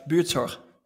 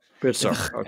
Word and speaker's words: Buurtzorg: Buurtzorg. – Buurtzorg.